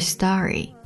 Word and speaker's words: story: You tell me your favorite story, you tell me your favorite story, told.失去爆破。You tell me your favorite story. Story. S-T-O-R-Y. St-t. 浊化成 d. Story.